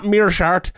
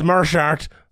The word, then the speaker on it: Meerschaert: All right, the second bet I'm going for here is Christoph Jatko to beat Gerard Merchart. I was on it this week, John Anik's podcast, and this was, it's not Merchart, it's Merchart. So there you go.